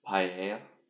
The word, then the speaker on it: paella: It's pronounced paella.